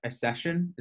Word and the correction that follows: Ascension: is that how I pronounce it? – Ascension, yes.